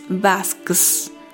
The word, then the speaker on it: Vasks: Si se lo preguntan, se escribe su nombre Peteris V a-S-K-S. Y suena así, Peteris Vasques, como Vasques pero con S-K-S.